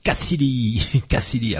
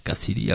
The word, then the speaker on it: Kcidy: Et puis, si vous préférez rester aux alentours de Châteauneuf de Gadagne, eh ben, allez donc voir Catfish et Cassili. Alors, ça s'écrit K-C-I-D-Y. Cassili, ah ouais.